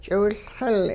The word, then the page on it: ch'iwiLxil: Hupa